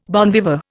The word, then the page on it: bon viveur: Indian